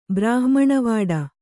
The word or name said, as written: ಬ್ರಾಹ್ಮಣವಾಡ